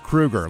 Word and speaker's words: Kroeger: It's pronounced Krueger, like Freddy. – Like Freddy Krueger.